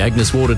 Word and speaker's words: Agnes Water: Heading down to Agnes Waters. Mate, it's pronounced Agnes Water.